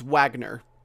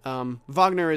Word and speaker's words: Wagner: You might have heard it's Wagner. Wagner is kind of how you pronounce it over in how the Slavs pronounce it.